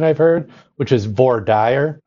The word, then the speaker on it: voir dire: So as we kick off this interview, I wonder if you could tell us, how do you pronounce that French term? – I have learned, because I think it all is learned behavior, it's voir dire is the way I learned it.